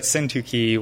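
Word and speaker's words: Syntyche: And so I just want to say I had quite the time figuring out how to pronounce that word, syntuche. I looked up many different pronunciations of how it might actually be pronounced. I was like, syntuche? Syntuche? But it seemed that syntuche was the clearest pronunciation.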